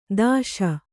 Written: ದೋಷಿ

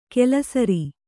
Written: ಕಲ್ಲುಸಾರ

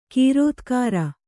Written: ಕೃತಿಕಾರ

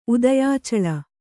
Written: ಉದಯಾಚಲ

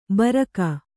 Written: ಬರಕ

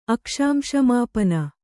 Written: ಅಕ್ಷಾಂಶಮಾಪನ

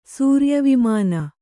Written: ಸೂರ್ಯವಿಮಾನ

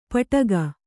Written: ಪಟ್ಟುಕ

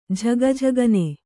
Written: ಝಗಝಗನೆ

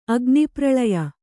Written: ಅಗ್ನಿಪ್ರಳಯ

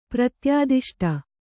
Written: ಪ್ರತ್ಯಾದಿಷ್ಟ

